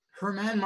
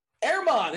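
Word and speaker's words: Germán: Herman Marquez, that's wrong. Maybe. Maybe I'm, I'm setting them up here. – I thought it was Marquez, too. Airmon. It's Airmon Marquez.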